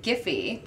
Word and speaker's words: Giphy: Jake is the head of sales and marketing at Giphy. Did I pronounce that right? – Yeah, hard G's for hard G's.